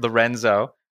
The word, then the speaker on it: Lorenzo: We have a very special guest on, Albert Lorenzo. That's how you say your last name, right? I didn't butcher that, right? – No, you're good, good. – Okay. – Like Lorenzo's oil.